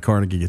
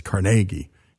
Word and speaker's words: Carnegie: And by the way, we did a tremendous hour on Andrew Carnegie, and Greg Hengler is always reminding us it's not Carnegie, it's Carnegie. And he's right, and so we want to say it right.